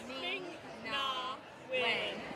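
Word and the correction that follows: Ming-Na Wen: Okay, when Ming Na Wen's went up there.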